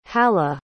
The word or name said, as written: halah